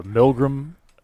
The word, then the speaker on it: Milgram: You know, it'd be so funny with the Milgram.